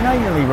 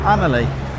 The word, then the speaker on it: Annerley: Awkward though, turns out we've been pronouncing Annerley wrong. – What did he say it was? – Annerley. I prefer Annerley.